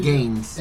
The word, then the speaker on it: Gaines: Es George Gaines. Creo que está pronunciando bien su apellido. – Sí. Gaines.